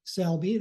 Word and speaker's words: Selbie: So I have a website with my name, the Joseph Selby, that's spelled S-E-L-B-I-E, somewhat unusual spelling of Selby.